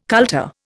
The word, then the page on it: kultur: Indian